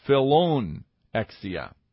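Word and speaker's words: Philoxenia: Just like we had Philadelphia, we now have Philorexia, which is a love to strangers. I don't know of any town named Philorexia, but I do know Philadelphia. I think I pronounced that incorrectly. It's Philonexia, Philonexia. Philonexia is a love of strangers.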